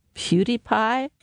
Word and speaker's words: PewDiePie: Well, it does bring us into influencers, and who is, and I'm going to mispronounce this, PewDiePie? – Oh, you actually got it. – Oh, it's right. It's right. It's called, let me spell it for you. It's P, capital P-E-W, capital D-I-E, capital P-I-E, PewDiePie.